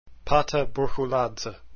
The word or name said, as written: Burchuladze